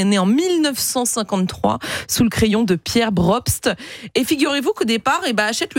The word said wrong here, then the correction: Probst: Pierre Probst, pardon pour la prononciation, c'est pas évident.